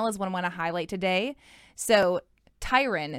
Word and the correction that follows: Taryn: is that how Taryn. – You say it? Taryn. So I said it wrong earlier and he corrected me.